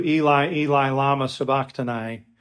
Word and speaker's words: Eli, Eli, lama sabachthani: We would pronounce it today in English, as most people do, "Eli, Eli, lama sabachthani."